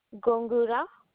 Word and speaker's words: Gongura: Gongura.